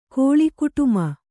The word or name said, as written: ಕೋಲುಕುಟುಮ